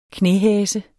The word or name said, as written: knæhase